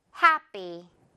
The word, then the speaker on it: happy: 뒷부분은 된 소리로 처리합니다. 강세 없을 때는요. 자, 듣고 따라해주세요. happy.